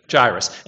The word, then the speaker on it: Jairus: How do you pronounce his name? Jairus, right? Jairus. And that's not what I've been saying, have I been? No, Jairus. Jairus, Jairus, Jairus. There's an I in there. I think when I made my hand out, I put the I in the other, I spelled it wrong, and I've been pronouncing like that.